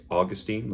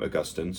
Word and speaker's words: Augustine: Some will say Augustine, like St. Augustine in Florida, named after him. But most theologians would pronounce his name Augustine, so Augustine, Augustine.